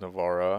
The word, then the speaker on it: Navarra: How do you say? It? Is it Navarre or it's, Navarra? It's Navarra. – Was it Navarra? Navarra. Navarra. Navarra. It's Navarra. Navarra. Yes. Yeah. Yeah. Navarra. That's how.